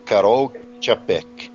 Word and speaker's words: Karol Čapek: Claro que essa é a pronúncia é bem diferente da maneira como se escreve. Karol Čapek.